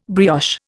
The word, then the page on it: brioche: Indian